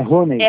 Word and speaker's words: Rony: Como é que fala o seu nome? Só pra não me chamar você por nome. – É Rony.